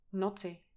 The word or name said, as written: knotty